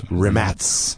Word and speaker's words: Rimac: Rimats, if you've never heard of them out there, is actually spelled R-I-M-A-C. – Yes. – And you probably think it's pronounced Rimac. – Yes. – But it's Rimats.